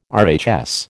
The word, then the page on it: RHS: American English